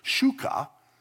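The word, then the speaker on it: shuka (ψυχή): It's actually pronounced shuka. Shuka.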